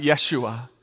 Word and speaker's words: Yeshua: In the Hebrew language, you might pronounce it Yeshua.